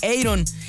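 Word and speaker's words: Eyron: Es como decir Iron Maiden, Iron Man, así se pronuncia Iron. La escritura es tal cual como se escucha, E y latina R-O-N, Iron,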